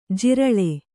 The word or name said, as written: ಜಿರಳೆ